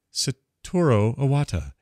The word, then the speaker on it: Satoru Iwata: Anyway, Nintendo's next generation system is being codenamed the NX, says Chief Executive Saruto Iwata. Satoru, how do you say it? Satoru Iwata.